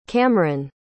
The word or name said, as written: Kamran